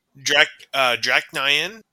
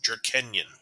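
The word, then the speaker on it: Drach'nyen: I've heard it pronounced two ways. Draknion. And the other way I heard it, which I actually think is really cool, and I could actually see it being more correct, is Drakenion.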